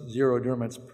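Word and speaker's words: xeroderma: Even though it's zero derma, it's spelled X-E-R-O derma.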